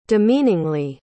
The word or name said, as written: demeaningly